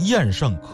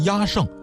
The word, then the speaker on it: 厌胜: 有的呢就属于是都行，您比方说有一种巫术叫厌胜，厌就是讨厌的厌，这个呀念成厌胜可以，念成压胜也可以。